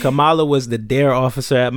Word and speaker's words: Kamala: I saw like like because you know like when everybody was like highlighting Kamala, I said it right.